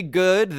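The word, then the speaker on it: Goode: Next, Gigi Good. The E is silent.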